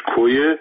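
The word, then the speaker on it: Koya: در کردستان عراق در شهر کوی سنجد یا به اصطلاح تلفظ کردیش کویه جلوی در ورودی ساختمان مرکزی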